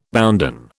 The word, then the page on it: bounden: British English